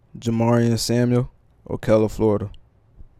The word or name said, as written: Jamarian Samuel